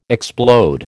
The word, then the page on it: explode: American English